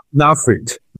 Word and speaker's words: NAFLD: von dem NAFLD, habe ich es jetzt richtig gesagt? – NAFLD. – NAFLD, genau, so hat man es ja lange genannt und es begegnet einem dieser Begriff ja auch immer noch, hin jetzt zu dieser MASLD.